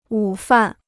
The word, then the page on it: 午饭: wǔ fàn